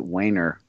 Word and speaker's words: Wiener: But he pronounced it Wiener for obvious reasons.